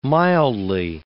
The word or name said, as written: mildly